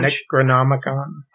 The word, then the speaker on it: Necronomicon: Necronomicon.